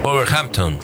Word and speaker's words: Wolverhampton: Wolverhampton.